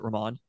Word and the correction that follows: Rahman: but it's Ramen. – Rahman, yeah.